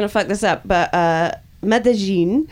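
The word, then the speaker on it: Medellín: Medellin, Escobar country.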